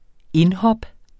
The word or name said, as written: indhop